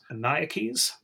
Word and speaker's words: Maniakes: So they sent the demand for surrender to the Roman commander, who is a man named George Manekes. If I'm pronouncing that correctly, M-A-N-I-A-K-E-S. Maniakes?